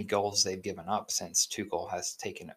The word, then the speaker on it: Tuchel: Haven't lost under their new head coach, Thomas Tuchel, I believe is how you pronounce his last name, T-U-C-H-E-L.